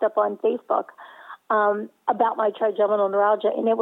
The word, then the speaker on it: trigeminal neuralgia: It has been crazy, and it's no fun to experience trigeminal neuralgia. Am I saying that right? – That's correct.